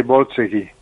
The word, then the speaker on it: Borzage: Por cierto, Borzage, lo he comprobado, se dice Borzage. – Yo no sé de qué forma... Como tuvimos un debate, ¿verdad? – Se escribe Borzage. – Yo he oído hablar de Borzage y se pronuncia Borzage, pero no se sabe por qué, porque él tenía sangre húngara.